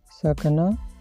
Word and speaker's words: sakna: Pronunciation this verb sakna, s-a-k-n-a, sakna.